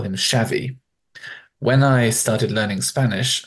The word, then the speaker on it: Xavi: It must be Xavi. But as it turns out, it's not Spanish, it's Catalan, and the X is sh, so it is indeed Xavi.